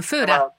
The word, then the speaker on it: Fora: Fora, non lo sai pronunciare, Stefano. Fora.